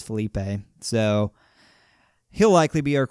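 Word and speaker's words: Feleipe: We have some news about likely our quarterback for next year, Felipe Franks.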